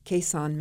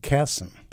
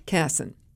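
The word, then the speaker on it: Kasson: Am I pronouncing the name of that town right? – You're close. It's pronounced Casson. – Casson.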